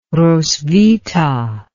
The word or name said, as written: roswitha